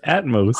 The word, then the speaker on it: Atmos: Why do you keep saying Atoms? – Atoms. – It's Atmos. – Atoms. Oh, is it Atmos? Oh, fuck. I pronounced it the wrong whole time, dude.